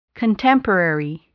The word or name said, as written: contemporary